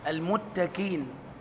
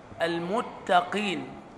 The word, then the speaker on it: المتقين: إذا نطقت كافا فهذا تحريف للكلمة. المتكين، كين كين، هذه الكاف خلاص، فالقاف تختلف عن الكاف. المتقين قين، وما قولش كين قين، وما قولش برضو المتقين، هو ده بقى انت بتعلي بقى القاف، قين قين مش قين قين.